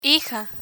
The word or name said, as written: hija